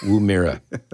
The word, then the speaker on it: Woomera: Well, probably because he mispronounced the place in Australia. – Woogamaru. What is it called? Oh, Woomera.